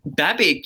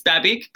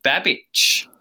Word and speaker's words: Babic: Babic, yeah, it's Croatian, so I think it's technically got an accent on the end. So, but yeah, it's Babic, but if you were in Croatia, you would pronounce it Babic.